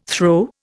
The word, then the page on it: throw: Indian